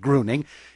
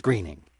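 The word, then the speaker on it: Gruening: Listener Fred Brown in Fairbanks, Alaska, called to correct my pronunciation, though the name is spelled as if it ought to be pronounced Gruning. He said he served with Ernest's grandson in the state legislature and that the family says Greening.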